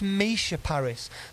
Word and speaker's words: Misha Paris: And when you've got an artist called Mika, who's M-I-K-A, you can see my mistake on this, but I got home yesterday. My mum went, it's not a criticism, it's constructive. It's Misha Paris.